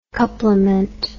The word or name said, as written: couplement